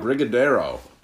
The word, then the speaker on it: Brigadeiro: But you can look up Springdale Barrel Room or just Jack's Abbey Brewing, and Abbey is spelled A-B-B-Y. – So how do we think we say this? Brigadeiro? – Brigadeiro. B-R-I-G-A-D-E-I-R-O.